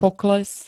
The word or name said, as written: pokles